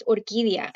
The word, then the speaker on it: Orquídea: So, you know, you say it in Spanish, it's Orquídea, you know, imagine people trying to say that being here in the United States.